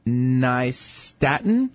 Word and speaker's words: nystatin: It has left me with oral thrush, and the doctor gave me liquid nystatin. Is that how you say it? Okay. – Yeah.